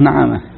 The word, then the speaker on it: نعامة: يعني هو الآن يعني ما أدري يعني في ضبط هنا حتى عندي هنا كاتب أبو نعامة في الحاشية قال هي بفتح النون يعني بفتح النون وضمها تحريف وهو نفسه ضابطه فوق فكأن هذا اللي يشتغل واحد وهذا يشتغل آخر لكن هنا يعني النعامة نعامة